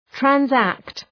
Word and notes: transact: træn’sækt

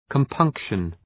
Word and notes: compunction: {kəm’pʌŋkʃən}

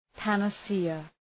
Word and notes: panacea: {,pænə’sıə}

